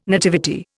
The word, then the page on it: nativity: Indian